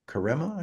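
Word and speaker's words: Karemma: Karema, I think is how you pronounce it. Karema. – Karema, thank you. – Yeah, I remember that because it sounds like caramel.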